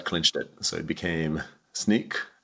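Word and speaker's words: Snyk: But the right pronunciation is Sneak, like sneaking out per the origin of the word.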